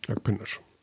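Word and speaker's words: Akpınar: Akpinasch. – Ja, also das, das, ähm, was das dazu führt, ist, dass dieses I Punkt hat, mhm. dann wird es ausgesprochen wie, wie das.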